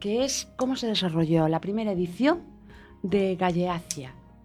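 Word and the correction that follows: Gallaecia: Lo dije bien, ¿no? – Galaecia, pero... – Galaecia, ¿cómo? – Galaecia.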